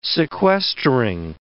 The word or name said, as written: sequestering